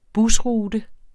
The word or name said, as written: busrute